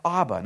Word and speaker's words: Abba: And the word that he often used was this Aramaic word that's pronounced Abba, not like the band. Well, it's the same word as the band, but not like the band at all.